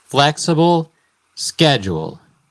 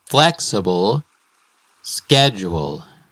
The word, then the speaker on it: flexible schedule: Flexible schedule. El énfasis está en la primera sílaba de flexible y en la primera sílaba de schedule. Flexible schedule.